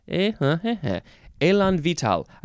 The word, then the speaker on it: élan vital: Now, where is the emphasis? Elan vital. I don't think there's any syllable that gets more emphasized. This is two words. The first word is exactly like the one about being vigorous and enthusiastic. Elan vital. The second word looks like vital. V-I-T-A-L.